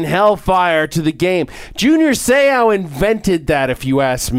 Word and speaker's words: Seau: Seau, I said, yes, you're pronouncing it right. Because as you will always remember, when Junior Seau hits you, you say, ow.